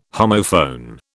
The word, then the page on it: homophone: British English